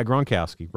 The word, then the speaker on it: Gronkowski: Good job, Gronkowski. And I'm giving him credits for that. Am I saying it right? – Yeah, Gronkowski, Rob Gronkowski.